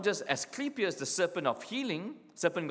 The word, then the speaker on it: Asclepius: And who is, Asclepius, let me pronounce that correctly. Asclepius was the serpent god.